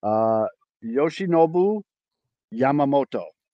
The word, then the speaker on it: Yoshinobu Yamamoto: Yoshinobu Yamamoto. – Oh, that was perfect.